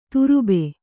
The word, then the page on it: ತರ್ಬು: kannada